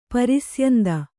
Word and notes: ಪ್ರಸ್ಯಂದಿ: prasyandi

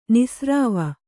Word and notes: ನಿಸ್ರಾವ: nisrāva

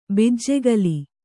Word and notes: ಬಿಜ್ಜೆಗಲ್: kannada

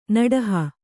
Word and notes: ನಡಹು: kannada; naḍahu

